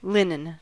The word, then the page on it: linen: American English